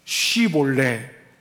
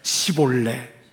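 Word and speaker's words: 쉬볼래 (שיבולת): 쉬볼래 라고 얘기를 하는 거예요. 쉬볼래. 근데 이거를 에브라임 사람들이 발음 못하는 거예요. 시볼래 라고 발음을 하는 거예요. 그러니까 쉬볼래가 안 되는 거예요. 그러니까 여린 시읒이 발음이 안 되면 죽인 거예요.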